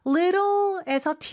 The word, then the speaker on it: little: 그래서 T 발음은 real처럼 발음이 부드럽게 됩니다. little에서 T도 역시 부드럽고요. sweeter의 T도 부드럽게 발음해 주시면 돼요.